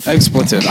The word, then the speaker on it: expletive: And explicative, did I say that wrong? – Expletive. – I don't know. Explicative. – I thought I pooped my pants. – Expletive. – I can feel expletive.